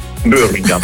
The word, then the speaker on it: Birmingham: Birmingham, ecco la pronuncia.